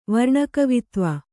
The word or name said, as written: ವರ್ಣಕವಿತ್ವ